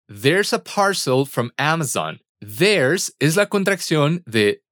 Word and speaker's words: Amazon: De Amazon, from Amazon. Cuidado con la pronunciación de la Z en inglés, que es z.